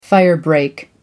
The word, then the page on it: firebreak: American English